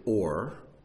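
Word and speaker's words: or (עור): But the word for light in Scripture is Aleph, Vav, Resh, and it's pronounced Or, O-R.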